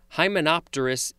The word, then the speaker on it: hymenopterous: Any of a family of colonial hymenopterous insects with a complex social organization and various castes performing special duties.